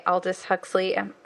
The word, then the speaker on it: Aldous Huxley: So Brave New World, as you might know, the classic novel by Aldous Huxley. I never know whether I'm saying that right. – Yeah, that's right. You're right.